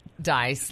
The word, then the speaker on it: Dyess: We were just talking about Johnny Cash's house in Dice, Arkansas. – It's pronounced Dice, like a die. – Yeah. – But it's spelled D-Y-E-S-S.